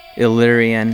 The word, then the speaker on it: Illyrian: Well, let's listen to the first track, Ona, where you explore your roots as a Illyrian. Did I say that right? – Mm-hmm.